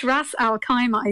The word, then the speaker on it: Ras Al Khaimah: For the last word on travel, travelexpert.ie, wants to bring us to a place called Ras Al Khaimah. Is that how I pronounce it correctly? – I think Khaimah, but yeah, pretty much Ras Al Khaimah, I think, is the exact pronunciation.